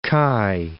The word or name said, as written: Cayes